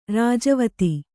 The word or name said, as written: ರಾಜವೀಥಿ